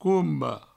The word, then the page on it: comba: coumbo